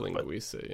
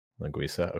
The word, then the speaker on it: Linguica: I don't know if it's a hard C or not. – Linguica, yeah. – Linguica, okay.